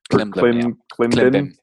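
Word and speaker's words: Klimbim: Off that same album, I think, is Klim Blim. – Klim Bim.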